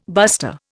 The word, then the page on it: buster: Indian